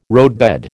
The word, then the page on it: roadbed: American English